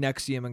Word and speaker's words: NXIVM: First, also, Nexium, it's, okay, Nexium is spelt N-E-X-I-U-M. That's not how it's spelt. It's spelt like N-X-V-U-M or something like that. It's spelt not how it's pronounced at all, and if that isn't the first red flag, I don't know what the fuck is.